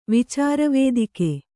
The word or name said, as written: ವಿಚಾರವೇದಿಕೆ